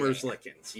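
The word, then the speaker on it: Merzlikins: Well, I'm going to butcher the last name. Mergelkinis. – I think I can give it to you. – Elvis, right? – Elvis. Okay, Elvis yeah, Merzlikens.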